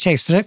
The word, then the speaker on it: Caesar: Ordet kejsare kommer verkligen från namnet Caesar, eller kejsar som det uttalades när det begav sig, på ett ungefär.